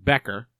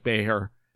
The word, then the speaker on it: Becher: Now, they were calling him Becher in this tournament. Cole Waldie, who is the play-by-play guy up in Prince George, pronounces it Becker. I'm assuming this is a European pronunciation, a North American pronunciation. You go to Elite Prospects and click on the little speaker next to his name, and it does sound like Becher. No K sound in there.